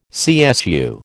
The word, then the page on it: CSU: American English